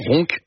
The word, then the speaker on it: Roncq: Le boulet à la bière, un fromage fabriqué à la ferme du Vinage à Roncq, je le prononce bien, c'est juste à côté de Tourcoing. – Exactement, Roncq.